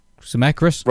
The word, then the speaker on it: Zemeckis: No, it was directed by uh, Robert C. Zemeck. Zemeckis. Robert Zemeckis. Oh, Zemeckis.